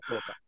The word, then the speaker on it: Fukuoka: They just, you're in, I'm terrible at Fukuoka. – pronunciation. – Fukuoka, yeah.